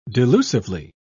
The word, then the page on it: delusively: British English